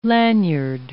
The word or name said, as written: lanyard